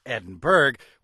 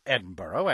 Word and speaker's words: Edinburgh: "One time while propping up the pub at a bar, I asked a local why the city of Edinburgh, which is spelled Edinburgh, was pronounced Edinburgh, and he replied, 'We're just like you Yanks. You have Pittsburgh, where all the steel comes from.'"